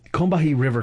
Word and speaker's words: Combahee: She also put out a book called How We Get Free, which is her both talking about the Combahee, God damn it, I cannot pronounce this right. Combahee River Collective. – Yeah, you said it right.